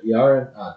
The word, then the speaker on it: Jarrín: Jarrín with the accent on the I, and Pepe Iniguez.